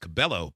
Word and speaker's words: Cabello: pronounced Cabello, but that is correct.